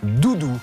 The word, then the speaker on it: dudu: Doudou, ça s'écrit D-U-D-U car c'est d'origine turque, mais ça se prononce Doudou.